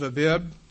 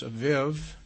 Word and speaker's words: Abib: This is the first of Abib, sometimes pronounced Aviv. I think that's just phonetically spoken.